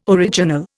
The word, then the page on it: original: Indian